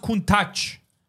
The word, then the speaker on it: Countach: It's not called Countach. Don't ever fucking say that. It's called Countach. – Okay. – It's actually pronounced Countach.